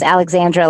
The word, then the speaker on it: Alexandra: It's pronounced Alexandra like anybody else who has that.